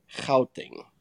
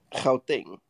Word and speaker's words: Gauteng: And then what you guys mentioned as Gauteng is Gauteng. – Get out of here. – That's clear your throat for the first part. Just say the rest of the thing.